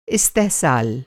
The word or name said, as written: इस्तेहसाल